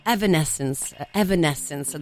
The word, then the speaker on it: Evanescence: Fondamentalmente Evanescence, quindi no, Evanescence dovrebbe essere la pronuncia esatta, solo che capisci, in Italia arriviamo con gli Evanescence o cose su questa linea, quindi potrebbe essere un po' complesso.